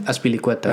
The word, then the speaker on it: Azpilicueta: Azpilicueta. Azpilicueta.